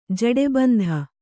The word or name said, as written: ಜೂಟಬಂಧ